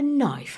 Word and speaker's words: knife: No one is pronouncing the K in the knife, right?